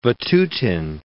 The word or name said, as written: Vatutin